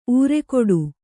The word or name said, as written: ಊರೆಗೊಡು